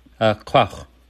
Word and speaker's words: Quach: Quach. It comes from Raven saying his own name when he was first created, like Quach, Quach, Quach.